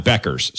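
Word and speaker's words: Bueckers: I think that's how you pronounce her name. I'm not great, to be fair. Buckers, that's right, right? And we got producer Greg, who's a big UConn. Beckers, sorry. Paige Beckers.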